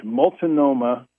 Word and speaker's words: Multnomah: And I do know that if you haven't been to, I'm pronouncing it correct, Multnomah Falls.